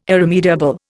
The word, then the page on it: irremediable: Indian